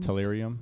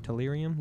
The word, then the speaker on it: tellurium: Tellurium? – Tellurium. – Tellurium, yeah, that's it.